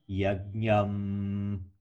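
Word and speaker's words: yajñam: The second one, yajnam parihishi prakshana, is pronounced ma because ba, the next letter ba, comes from your lips, yajnam. So the anuswara, this yajnam, also comes from your lips, which makes it ma.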